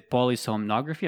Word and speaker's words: polysomnography: So you mentioned in the sleep quality, there's a polysomnography. Am I pronouncing it right? – Mm-hmm.